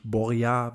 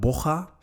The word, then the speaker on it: Borja: Also man hat ihn mal gefragt, wie der Vorname ausgesprochen wird. Und das heißt nicht Borja, wie ich das letzte Folge gemacht habe, sondern Borja.